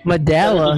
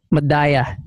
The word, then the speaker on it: Medalla: Medalla.